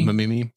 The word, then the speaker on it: Mamimi: Mamimi.